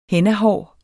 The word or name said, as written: hennahår